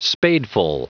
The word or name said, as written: spadeful